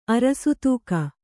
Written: ಅರಸತೂಕ

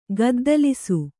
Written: ಗದ್ದಲಿಸು

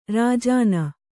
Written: ರಾಜಾಣ

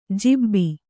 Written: ಜೀಬಿ

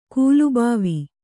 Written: ಕಲ್ಬೋವು

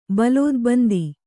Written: ಬಲೋದ್ಬಂದಿ

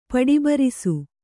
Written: ಪಡಿಬರಿಸು

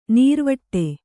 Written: ನಿರಿವಿಡಿ

